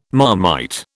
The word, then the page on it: Marmite: British English